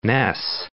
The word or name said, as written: Ness, Loch